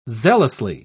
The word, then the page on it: zealously: British English